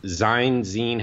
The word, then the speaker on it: zine: For those who don't know what Death to the World is, Death to the World is a zine. How do we pronounce this word? – Zine.